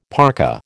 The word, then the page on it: parka: American English